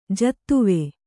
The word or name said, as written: ಜತ್ವ